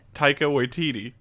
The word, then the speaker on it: Taika Waititi: I can never say his name. – I don't even want to say it. I just love that. – Watiki Taki, that's his new name. No, really, what is it? – Taika Waititi.